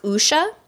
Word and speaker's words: wuxia: And it is pronounced wuxia.